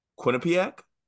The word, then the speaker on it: Quinnipiac: Isn't that what it is? Quinnipiac. Quinnipiac. You're just putting a lot of emphasis on the "ia." – Quinnipiac. – Quinnipiac. Quinnipiac. It's Quinnipiac. You were saying it differently before, but go on.